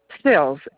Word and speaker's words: Sills: Sills.